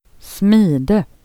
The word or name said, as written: smidena